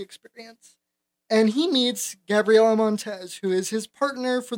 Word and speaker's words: Montez: I think it's Montez.